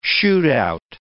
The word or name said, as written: shoot-out